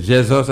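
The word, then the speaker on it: Jeezus: So we have DJ Fire there also, and we have DJ Jesus from Guadeloupe. – Oh, okay. Jesus, that's how they pronounce it? – Yes. – Oh, because it's spelled J-E-E-Z-U-S. I almost said Jesus.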